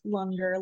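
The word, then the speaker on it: Langar: It's spelled Langer, but it's pronounced Lunger, like hunger.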